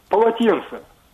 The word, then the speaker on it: полотенце: Полотно, льняное полотно, маленькое полотно, это полотенце. Переставляем ударение, получаем полотенце.